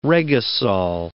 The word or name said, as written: regosol